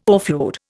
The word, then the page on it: offload: Indian